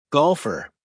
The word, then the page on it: golfer: American English